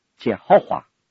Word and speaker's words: 耶和华: 单单这四个字母很难让人了解，所以他就加上母音，加上母音，在 Y 的后面加上一个 A，YA， H 的后面加上一个 O，W 的后面加上一个 A，因为加上这三个母音就读成为耶和华，耶和华。